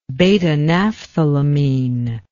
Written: beta-naphthylamine

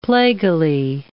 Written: plaguily

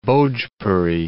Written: Bhojpuri

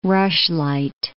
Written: rushlight

